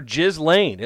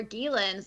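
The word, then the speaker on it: Ghislaine: He called her Jizz Lane.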